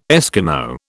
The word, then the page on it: Eskimo: British English